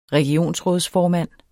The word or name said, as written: regionsrådsformand